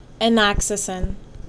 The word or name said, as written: Enoxacin